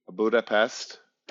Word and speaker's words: Budapest: And now Luke has brought me, Luke lives in Belgium, and he went to Budapest. That's how you say it in Hungarian.